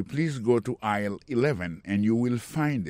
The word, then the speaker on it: aisle: Go to aisle 11 and you will find it. Aisle là, c'est A-I-S-L-E. Vous prononcez aisle.